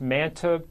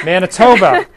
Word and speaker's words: Manitoba: And if I pronounce this right, it's Manitoba. Manitoba.